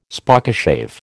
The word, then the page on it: Spokeshave: American English